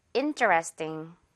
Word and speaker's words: interesting: interesting보다는요. 띵, 띵에 가깝게 그리고 조금 많이 죽여서 강세를 죽여서 발음을 해줄 거예요. interesting. 이 정도로. 자, 듣고 따라해 주세요. interesting.